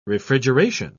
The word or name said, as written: refrigeration